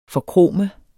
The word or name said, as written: forkrome